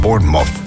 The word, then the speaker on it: Bournemouth: Je moet het volgens de Engels uitspreken als Bournemouth. Bournemouth. Een beetje inslikken.